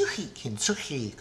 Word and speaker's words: kintsugi: kinsui and this Chinese word, kinsui, two words, kintsugi,